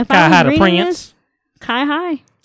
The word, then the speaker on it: CyHi: C Y H I. – C Y. Yeah, I don't. – How do you say it? Kai Hai.